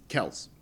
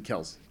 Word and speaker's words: Kelce: So the answer to your question is apparently the way it's supposed to be pronounced is Kels. Neither Kelce nor Kelce. It's supposed to be Kels.